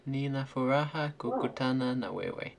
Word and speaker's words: Nina furaha kukutana na wewe: Do you want to correct my pronunciation at all? – It was actually quite good. Yeah, Nina furaha kukutana na wewe.